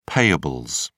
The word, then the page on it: payables: British English